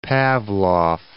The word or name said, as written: Pavlof